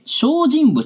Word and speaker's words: 生身: 仏像の世界ではですね、普通のお像とは異なって、生きていると認識されてきたもののことをですね、生身と書いて、証人物などと言っています。その証人物の代表格であるのがこの清凉寺のお像。実はこの証人ということを演出するために、体の中にとんでもないものが仕込まれていました。